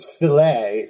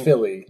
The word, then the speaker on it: filly: So she prefers to pronounce it as filet rather than filly, you know, as a...